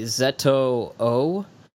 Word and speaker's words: ZO: Well, I do know the Japanese, like, phonetically, the Japanese say Zeto-O, and Zeto, I think, is, like, the Japanese word for the letter Z, so...